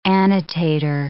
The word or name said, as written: annotator